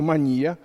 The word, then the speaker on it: мания: Вот есть настоящая мания, или, как говорят психиатры, мания. У психиатров свои ударения,